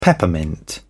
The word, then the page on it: peppermint: British English